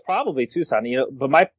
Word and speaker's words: Tucson: A practicing dentist in Tucson, Arizona, pronounced Tucson.